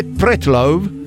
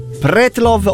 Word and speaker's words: Pretlove: Ma sarà sulla E o sulla O l'accento? Pretlove o Pretlove? – Ma no, allora, è Pretlov. Petrov, diciamo, è più russo, Pretlov, secondo me, e non avrebbe la E finale.